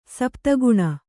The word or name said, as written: ಸಪ್ತಗುಣ